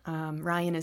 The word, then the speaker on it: Ryane: Ryan is spelled R-Y-A-N-E, so the E helps everybody find me.